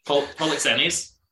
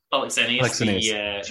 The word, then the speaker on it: Polixenes: Polixenes. Polixenes, the Bohemian king.